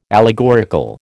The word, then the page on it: allegorical: American English